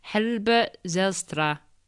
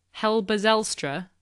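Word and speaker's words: Halbe Zijlstra: I'm about to wreck the pronunciation of this. How's this, Dominic? Helbert Zijlstra. Something like that? – Yeah, I think you could say it more in more of an English accent, actually.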